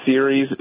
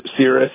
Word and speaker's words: Ceres: They're called Ceres. Is that how I pronounce it? Ceres? – Yeah, I think it's Ceres. Ceres, yes.